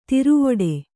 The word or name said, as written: ಥರವಿಡಿ